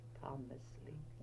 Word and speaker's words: Tomsli: Yeah, T-O-M-S-L-I, Thomasley. – And what about your maiden name? How did you spell that? – S-O-R-E-N-G.